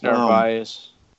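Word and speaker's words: Narváez: Is that how you say it? Narvaez? – Yeah, it's Narvaez.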